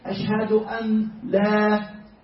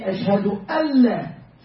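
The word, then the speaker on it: ashhadu alla (أشهد ألا): So when we pronounce it, we should not say ashhadu an la. We should say ashhadu alla. So the noon is gone.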